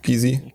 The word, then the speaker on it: Gysi: Kennt ihr Gysi? Oder wie Gysi ausgesprochen? – Ja. – Gysi. – Klar.